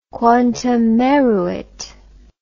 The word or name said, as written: quantum meruit